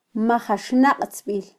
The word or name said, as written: majaxnaqtzbil